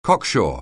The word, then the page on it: cocksure: anglais